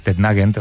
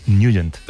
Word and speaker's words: Nugent: Hombre, siempre lo hemos llamado en el barrio Tednagent o Tednagent. – Claro, ¿verdad? Yo era de Tednagent. – Pero la pronunciación correcta es Nugent.